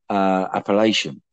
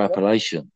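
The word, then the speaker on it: Apalachin: And especially after Appalachian. Oh, yeah, I said it right. Yeah. Especially after Appalachian,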